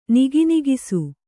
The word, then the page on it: ನಿಗಿನಿಗಿಸು: Kannada